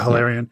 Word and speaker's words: Hilarion: One other thing I really liked that I would like to steal is I loved how Tyrion couldn't see Hilarion. Am I saying his name right? Yeah, Hilarion. Yeah.